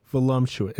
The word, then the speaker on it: voluptuous: Volumumptuous. Voluptuous. It's voluptuous. – There's no M.